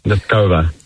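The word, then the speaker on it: Nosková: Muchova and Noskova.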